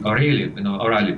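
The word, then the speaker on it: O'Reilly: Дело в том, что Билл О'Райли в России, О'Райли, правильно произносить его, он не корреспондент.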